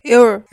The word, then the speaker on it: Earl: Eu não consigo falar Earl. Como é que fala? – Earl. – Earl. Você tem o Earl, parece que tá falando eu com sotaque mineiro, né?